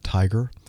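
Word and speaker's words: TIGHAR: And there's an organization called, the International Group for Historic Aircraft Recovery, T-I-G-H-A-R. I guess they pronounce that TIGER,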